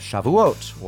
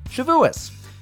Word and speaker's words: Shavuot: Every year, we make a conversion episode in honor of the Jewish holiday of Shavuot, or as some people say with the Yiddish pronunciation, Shavuos.